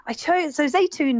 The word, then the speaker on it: Zaytoun: Let's start with the one that you chose for the title of your book, and I hope I'm saying it right, Zaytoun, the olives of the region. – Yes. So Zaytoun means olive in Arabic, but also Turkish and Farsi.